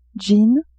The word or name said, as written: jean